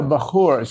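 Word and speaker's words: bakhoor: So is it wood the same as bahur? I'm saying it wrong. I'm sorry. – Yeah, bahur. So bahur is an Arabic term.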